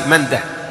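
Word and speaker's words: منده: قال: وقال ابن مندة، وكان أبو داود، ابن مندة، مندة دائما بالهاء، مندة وقفا ووصلا، اسم فارسي، مندة، مندة وسيدة، ابن سيدة أيضا بالهاء وقفا ووصلا، وماجه، هذه الثلاثة كلها بالهاء، ماجه ومندة وسيدة، ما يقال ماجة مندة، لا خطأ، لأنها أسماء فارسية تنطق بالهاء وقفا ووصلا.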